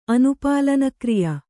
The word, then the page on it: ಅನುಪಾಲನಕ್ರಿಯ: anupālanakriya